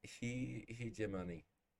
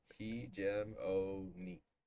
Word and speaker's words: hegemony: Hegemont. Maybe I'm saying it wrong, but yes. – It's like hegemon. – Hegemont. Yeah. – Hegemony. – Yeah, so. – Hegemony. – Yeah, that's the right term.